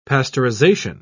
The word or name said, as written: pasteurization